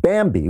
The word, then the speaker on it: Bambee: But Bambi, which is spelled B-A-M-B-E-E, was created specifically for small businesses.